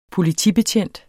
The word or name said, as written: POLITIBETJENTES